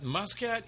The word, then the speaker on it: Muscat: Muscat. – Muscat. – Which they pronounce Musca.